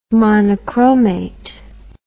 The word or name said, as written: monochromate